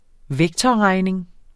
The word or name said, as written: vektorregning